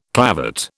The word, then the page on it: clavate: British English